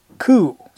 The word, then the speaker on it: Couc: Information on the Pennsylvania Historical Society website indicates she may have been Catherine Couc, C-O-U-C, and you French linguists, please excuse that pronunciation, who, according to baptismal records, was born in 1684, the child of French fur trader Louis Couc and an Indian wife.